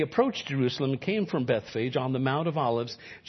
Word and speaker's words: Bethphage: As they approached Jerusalem then and came to Bethphage, can I tell you, it's not pronounced Bethphage. The E is a long E in the Greek, so it's Bethphage.